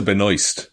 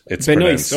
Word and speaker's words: Benoist: I think Benoit. – Supergirl? – Supergirl. Yeah. I don't know how to pronounce her name, but she's very good at Supergirl. – I think it's actually Melissa Benoist. – Benoist, okay.